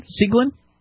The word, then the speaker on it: Tseglin: I'm going to try his last name, Seglin, T-S-E-G-L-I-N, Seglin. I'm presuming the T is silent.